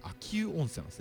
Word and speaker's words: 秋保: で、これ漢字がずっと読み間違えてたんですけど、これ秋保温泉と書いて秋保温泉なんですね。保ってのは保つという漢字なんです。これうと読むようで、これ秋保温泉というところなんですけど、泊まりに普通に行きまして、綺麗なんですよね。